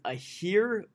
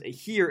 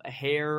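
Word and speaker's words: Aher: I thought, the Uguak part wasn't as hard for me, you know, but it was, learning that it was Ahir instead of a hair or however else you would pronounce it.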